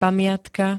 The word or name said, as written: pamiatka